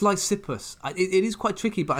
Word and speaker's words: Lysippus: And that is the famous slash infamous Greek sculpture Lysippus. Did I pronounce it right this time? – You did, but you said sculpture, not sculptor.